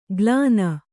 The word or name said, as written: ಗ್ಲಾನ